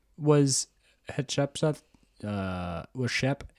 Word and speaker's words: Hatshepsut: Oh, by the way, her name is spelled, just in case you want to Google it, H A T S H E P S U T.